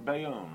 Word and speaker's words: Bayonne: Anyway, that was our friend Hirsch Silverman, Herschel Silverman, who lives in, how do you pronounce Bayonne? Bayonne, right? – You said it, yeah.